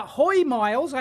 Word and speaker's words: Hoymiles: It's the uh, Hoymiles. I have been told that is the correct pronunciation.